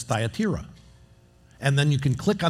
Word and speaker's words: Thyatira: Thyatira. Thyatira.